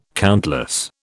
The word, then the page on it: countless: British English